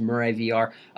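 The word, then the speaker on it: Mure VR: If you guys want to check out, if you just look it up, it's spelled M-U-R-E space VR. It's pronounced Murray VR.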